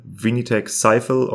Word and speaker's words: Vinitech Sifel: It is spelled V-I-N-I-T-E-C-H-S-I-F-E-L. Vinitech Sifel, or how you pronounce it in français, Vinitech Sifel.